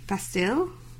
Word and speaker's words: Bastille: So I made watch Happier video by Marshmallow and Bastille. – Bastille? – Bastille. You know that in French it's Bastille.